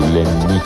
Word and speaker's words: Ляни: Квартира принадлежала его другу, манекенщику Ляни. С ударением на «и», уточнил он.